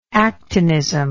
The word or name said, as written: actinism